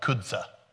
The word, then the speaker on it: Chuza: Actually, I looked up the pronunciation for it, and it's like kudza. Kudza. I don't like that. I like chusa better.